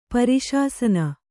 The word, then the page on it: ಪರಿಶಾಸನ: pari śasana